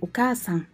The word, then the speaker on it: お母さん: trọng âm của từ okasan là nhấn vào âm ca.